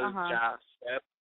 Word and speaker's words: Yasser: The name is Arabic, and I guess the Spanish way to pronounce it will be Yasser, as in, Yasser. – Like, the J instead of the Y is pronounced. So Yasser, like, yes, sir, you know.